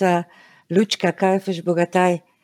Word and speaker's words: Lučka Kajfež Bogataj: Well, my name is correctly pronounced Lučka Kajfež Bogataj. Lučka is like Lucy in Lucy in English.